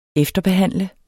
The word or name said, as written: efterbehandle